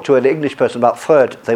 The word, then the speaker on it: Freud: You'll notice that in French we often like to make the pronunciation French. So the founder of psychoanalysis in French is Freud. Whereas in England we prefer to approximately imitate the original, and so we say Freud.